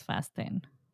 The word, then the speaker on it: Fast X: Someone said in the film cast that apparently it's Fast 10. That's the way the director wants you to say it.